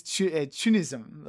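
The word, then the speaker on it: CHUNITHM: Yeah, so the katakana, yeah, is Chunism, which I imagine is just the Japanese way.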